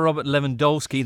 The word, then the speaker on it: Lewandowski: A hat-trick for Robert Lewandowski, that's how it's pronounced, who's now only the second player to score 300 Bundesliga goals ever, after Gerd Müller.